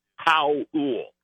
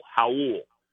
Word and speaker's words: Raul: Well, even though it's spelled with an R, the correct Brazilian pronunciation, he's from Brazil, is Howell. Like, how are you doing? Ool. Howell. So Howell Neto, and it is Lauri Markkanen.